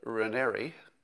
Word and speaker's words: Raniere: So Allison Mack was sort of the, you know, people called her like the second in command at NXIVM under Keith Raniere, rhymes with canary, I'm told.